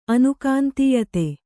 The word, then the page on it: ಅನುಕಾಂತೀಯತೆ: anukāntīyate